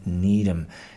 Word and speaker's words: Needham: That's Sean Needham. Think of, who needs ham? Because that's how it's spelled. Need, N-E-E-D, ham, H-A-M, Needham.